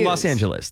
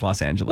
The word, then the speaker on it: Los Angeles: It's Los Angeles. That's how people say it. Los Angeles. – Well, right. But like, are you saying, okay, sure. – Anyway, I think like 99% of the people would agree that it's pronounced Los Angeles.